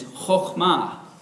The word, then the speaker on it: chokhmah: The Hebrew word for wisdom is chokhmah. Can you guys say that? Chokhmah. I checked with Sarah yesterday to make sure I was pronouncing that correctly.